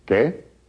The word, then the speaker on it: qué: The Q in Spanish is always followed by a U when written, but this U is never pronounced. Repeat. – ¿Qué?